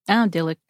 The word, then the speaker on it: odylic: And it is spelled O-D-Y-L-I-C. – Oh, Dillick. Yeah, okay, gotcha.